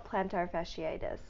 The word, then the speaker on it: plantar fasciitis: Pretty severe plantar fasciitis or plantaritis. – That was a really incredible mispronunciation. – How do you say it? – It's called plantar fasciitis.